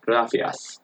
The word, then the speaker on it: gracias: So there's a word like gracias, which means like thank you in Spanish. In Spain, instead of the C in the middle, it's like gracias. It's almost like a TH, like gracias.